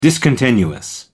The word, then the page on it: discontinuous: American English